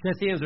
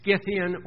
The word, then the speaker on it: Scythian: Scyth or Scyth is S-K or S-C, so it's sometimes done with a soft C, but it can easily be done with a hard C. Scythian or Scythian, depending on who's saying it.